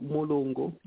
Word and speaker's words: Mulungu: When you look at the word Mulungu, in Zambia there's a word called, we saw Mulungu. It rhymes with Muzungu.